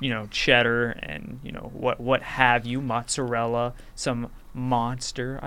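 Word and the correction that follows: Muenster: I believe. Is that how it's pronounced? – I believe it's pronounced Munster. – Okay, Munster. All right.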